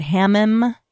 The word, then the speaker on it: hammam: Is it hammam? – Yes.